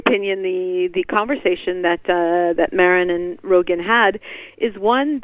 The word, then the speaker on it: Maron: Well, before I get to the riled up, his name is pronounced Maron, but I think most Israelis these days would pronounce it Moron, so it's very appropriate that you said Maron.